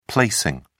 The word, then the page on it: placing: British English